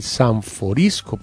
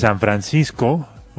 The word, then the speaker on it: San Francisco: O como dicen, hombre, los niños, en Sanforisco, porque no la pueden pronunciar.